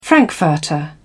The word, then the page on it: frankfurter: British English